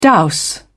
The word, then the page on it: dowse: British English